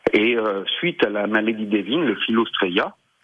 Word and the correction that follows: Phylloxera: mhm. je le prononce mal, excusez-moi. – Phylloxera, oui, c'est pas grave.